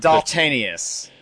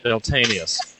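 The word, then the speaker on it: Daltanious: And there's actually a fourth called Deltinus or Delta Anus or something like that. I'm not too sure how to pronounce it. – Deltanius. – Deltanius.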